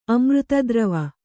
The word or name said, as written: ಅಮೃತದ್ರವ